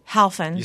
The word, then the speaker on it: Helfand: How do you pronounce it, Charlie? – Helfand. – You see? – Where I come from, there's no N. It's just Helfand.